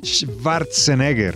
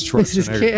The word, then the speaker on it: Schwarzenegger: Pues estaba buscando y en alemán o en, en Alemania o en Austria es Schwarzenegger. – Imagínese. – Schwarzenegger. Es una aproximación, ¿no? – Uno dice la pronunciación como perfecta, Schwarzenegger. – Por favor. Pero es válida la pronunciación en inglés, que es Schwarzenegger. – Es que es un poco complicado. – O Schwarzenegger en español.